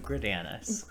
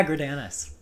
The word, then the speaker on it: Greidanus: Nobody gets my last name correct. It's Luke Gridanis. Happy to be here. – It actually is. – Yeah, Gridanis.